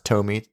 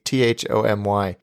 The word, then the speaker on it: Thomy: And I asked him right before we came on to record, I said, are you talking about, I think it's pronounced Tomy, Thomy.